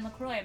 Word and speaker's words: LeCroy: Oh, you can follow me on Instagram at Eryn LeCroy. My name is spelled with a Y. E R Y N L E C R O Y. Not like the drink.